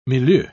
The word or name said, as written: milieu